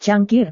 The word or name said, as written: cangkir